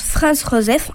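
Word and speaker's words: Franz Joseph: François-Joseph Ier, qui se prononce Franz-Joseph en allemand, naquit le 18 août 1830 pour monter sur le trône en 1848 à l'âge de 18 ans.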